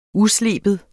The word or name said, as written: usleben